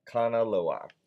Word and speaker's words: Kanaloa: Kanaloa. Kanaloa.